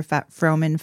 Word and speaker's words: Froman: And then Froman is my maiden name. So F-R-O, like Fro, and then Man, M-A-N. Fowler is F-O-W-L-E-R.